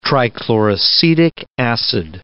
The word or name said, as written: trichloracetic acid